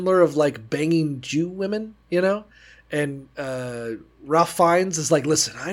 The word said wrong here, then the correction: Ralph: Ray Fiennes, but yeah. – Is it Ray or Ralph? – It's Ray. It's spelled like Ralph.